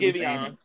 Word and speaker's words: Giveon: Yeah, Giveon.